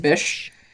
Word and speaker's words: Bysshe: I never knew how to pronounce his middle name. Percy Bysshe Shelley. It's just Bysshe.